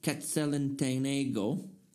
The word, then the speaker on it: Quetzaltenango: I am in the field in Quetzaltenango. Did I say it right? – It's close enough. – Close enough? Yeah. Okay, I said it right.